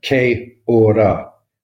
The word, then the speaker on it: Kia Ora: So it should actually be said Kia Ora.